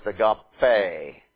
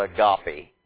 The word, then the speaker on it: agape: First of all, it's sloppy because it's pronounced agape, not agape.